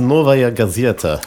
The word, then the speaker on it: Novaya Gazeta: It's Novaya Gazeta.